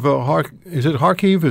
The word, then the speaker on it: Kharkiv: The city of Kharkiv, is that how you pronounce it? – Yes, exactly.